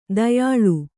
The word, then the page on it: ತೇಯೆಲೆ: tēyele